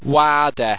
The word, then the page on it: waarder: owstwecian